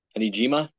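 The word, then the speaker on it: Amijima: So this film is based on the love suicides of, see if I can pronounce it correctly. – Anijima? – Anijima, yes.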